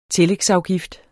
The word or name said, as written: tillægsafgift